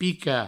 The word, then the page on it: picar: Provençal; pica